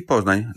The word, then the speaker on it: Poznań: In City Poznań, there is Uniwersytet Medyczny imienia Karola Marcinkowskiego w Poznaniu, where Harald Wallach was working.